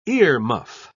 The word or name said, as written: earmuff